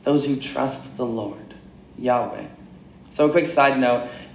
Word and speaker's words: Yahweh: And we pronounce it as Yahweh.